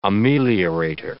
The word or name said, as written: ameliorator